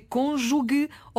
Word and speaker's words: cônjuge: É cônjuge ou cônjuge? – É curioso, porque ouvimos das duas maneiras, não é? – Ouvimos. Cônjuge. Não. A pronúncia correta é cônjuge.